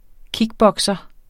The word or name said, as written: kickbokser